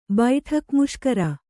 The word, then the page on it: ಬೈಠಕ್‍ಮುಷ್ಕರ: kannada